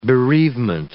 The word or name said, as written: bereavement